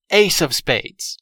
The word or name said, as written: ace of spades